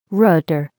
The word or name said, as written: rotor